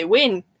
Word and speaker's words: wean: They're not the Ulster Scots, W-A-I-N, because they say Wayne. Mm-hmm. That's how they say it. I say Wayne, W-E-A-N.